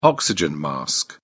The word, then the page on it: oxygen mask: British English